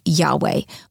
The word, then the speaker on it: Yahweh: It is written, spelled YHWH, and probably pronounced Yahweh. We don't know if this is the way it was pronounced at the time because the Hebrews did not use vowels. They only used consonants.